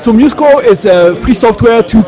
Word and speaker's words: MuseScore: And did I mispronounce the name of the project? – The name of the project is MuseScore. I think you were right.